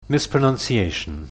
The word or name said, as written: mispronunciation